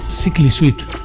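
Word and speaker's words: Cichli Suite: En Vain y Thistle Suite, que es el que iba a decir antes, que me he equivocado, pronunciado, por cierto, Sickly Suite. Ojalá hubiera leído eso antes.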